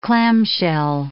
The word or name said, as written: clamshell